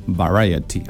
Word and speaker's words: Variety: Variety, así se pronuncia.